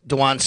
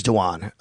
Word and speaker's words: Dwan Smoot: And just so everybody knows, it is, I know it's spelled like Dwayne, it is Dwan. You know, I checked in on that. Just, it is Dwan Smoot, and he is also playing possibly.